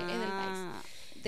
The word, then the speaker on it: Edelweiss: Y toda la línea que era parte de esa línea de gotas de juventud, y ahora se llama Edelweiss. O se dice Edelweiss. Una chica me corrigió, pues dijo, esto es una palabra alemana, no es en inglés, así que Edelweiss.